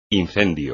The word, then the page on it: incendio: espagnol